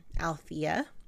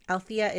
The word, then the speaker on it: Althea: I also, you may have noticed, or maybe you didn't, that I am still pronouncing my first name, Althea, kind of without the first E. The first E is silent.